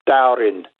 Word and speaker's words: Dowerin: Dowerin, D-O-W-E-R-I-N, obviously an inland town. – Yes, well, our 28th parrot here, The Noongars here call him Dowerin, and they enter D's and T's because they're very similar when you pronounce them. So if you say Dowerin or Dowerin, one with a D, one with a T, it's the same.